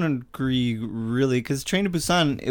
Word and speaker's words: Busan: And then in Train to Busan, am I saying it right? – Yeah.